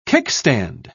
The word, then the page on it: kickstand: British English